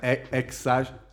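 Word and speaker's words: X Æ: si pronuncia ex Ash.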